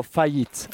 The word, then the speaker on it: faillite: So you've got échec, an échec, like the chess game, échec, or faillite. More than fracaso, yeah. – Okay, faillite.